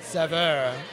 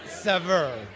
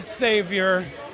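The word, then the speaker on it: Saveur: If I were in France, I would probably say Savour. But since I'm in America, I say Sever. And probably the strangest way that I've ever heard it pronounced is Savior, which I gotta say is a little weird, but also kind of wonderful.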